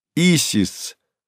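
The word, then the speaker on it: Исида: А если заглянуть дальше, вглубь веков, выяснится, что имя великой древнеегипетской богини Исиды, которая в современном арабском произносится как «Изис», звучало как «Исис», причем во втором варианте окончание «ис» появилось из греческого.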